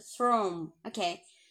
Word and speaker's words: thrown: OK，我们现在看，我们继续讲哈。我们这个它是 throw，对吧？throw，OK，那它变成过去分词形态的时候，它是变成了加了 n，它就发音变成了 o，thrown，thrown，OK，大家注意它的发音，一个 throw，一个是 thrown，OK，啊，它有这个 n 的音在里面。